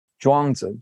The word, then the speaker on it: Zhuangzi: And those of you that have read the Taoist sage Zhuangzi, the name sometimes gets pronounced differently, but it's, when I learned it, it was Zhuangzi.